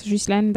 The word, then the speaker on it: Ghislaine: I think it's Ghislaine. That's how it's said in French.